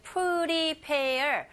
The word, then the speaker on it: prepare: 자, 준비하다 라는 동사 발음하실 때 prepare. 강세가 pair 뒷부분에 있어요.